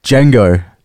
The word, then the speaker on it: Django: Django.